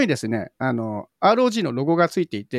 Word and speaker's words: ROG: で、 ASUS まだまだね、いろんなのも出してて、スマホも今回ですね、 ASUS ブランドの、ゲーミングスマホ、 ROG Phone 8 海外発表、より薄く軽くなって IP68 対応、カメラも強化というところで、ASUS が ROG というゲームブランドらしいんですけど、これローグって読んじゃダメなんですよね。正式には ROG っていう、うん、エースは一個一個ね読むの難しいんですよね。